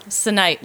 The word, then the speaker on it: Sunite: Sunite, like sun and night together.